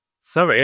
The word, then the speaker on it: SEVI: And the acronym we are talking about today is, and I'm not sure whether I say it correctly, SEVI. Or how do you pronounce it? – SEVI, yeah. SEVI is correct.